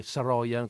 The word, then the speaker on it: Saroyan: fra l'altro eh, in armeno probabilmente si pronuncerà in maniera diversa, non lo so, perché Saroyan con l'accento sulla O è all'americana, non ho idea di come si pronuncia in armeno, non mi arrischio.